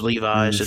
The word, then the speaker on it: Levis: But Will Levis. Seemed like he never developed. – It's not pronounced Levi's. It's Levis.